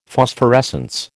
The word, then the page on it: Phosphorescence: American English